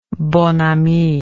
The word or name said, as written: bonne amie